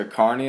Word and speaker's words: Kearney: You earned your degree from Nebraska Kearney. Am I saying that correctly? – That is correct. That's how you say it.